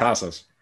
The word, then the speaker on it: Casas: Casas.